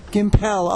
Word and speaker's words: Gimpel: I am live at Trek Long Island, and I am very honored to be here with Sandy Gimpel. And is am I pronouncing your name right? – No. It's Gimpel. – Gimpel. Oh, you put the French thing on.